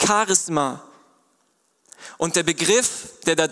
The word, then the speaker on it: Charisma: So wie wir es in der Bibel finden, wird es ausgesprochen, falls ihr es nicht lesen könnt, eine Charisma. Man kann auch sagen Charisma und dieses Wort begegnet uns auch in Charismatik.